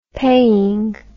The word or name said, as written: paying